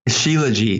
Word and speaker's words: Shilajit: Shilajit.